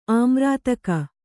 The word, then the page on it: ಆಮ್ರಾತಕ: Kannada